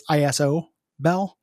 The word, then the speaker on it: Isobel: The orc shaman is named Gobbit, and the dwarf decker is named Isobel, but it's ISO Bell, spelled like an ISO.